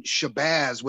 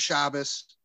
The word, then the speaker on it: Shabbos: And I kept saying Shabazz with a long A and Z. But when I went back and looked at it, I was wrong. He's saying Shomer Shabbos,